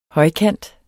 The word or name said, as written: højkant